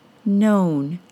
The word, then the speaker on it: known: However, I think some English language learners don't trust that they are hearing a different pronunciation in words like grown, G-R-O-W-N, and known, K-N-O-W-N. Both of those words are spelled O-W, but pronounced with a long O sound, O, and not the OW sound, ow.